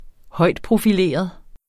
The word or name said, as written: højtprofileret